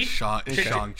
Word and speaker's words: Shang-Chi: But Shanghai at 2, you kidding me? – Well, how do you pronounce it? You said Shanghai? You said Shanghai? – Shang-Chi.